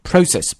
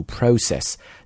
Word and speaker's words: process: Process 表示流程的意思，注意这个单词在发音的时候呢，英式英语会对这个 O 呢发它字母的音，就读 process。那么美式英语呢会把它读成 process, 往 R 上面发。 So process or process both are fine。